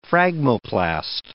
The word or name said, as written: phragmoplast